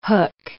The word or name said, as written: hook